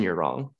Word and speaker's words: Yurong: Same thing, Justin, Yurong.